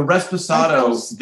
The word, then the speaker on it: Reposado: The Reposado. This Reposado. You said. Oh, you did say it right.